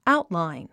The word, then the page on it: outline: áutlàin; アウトラァイン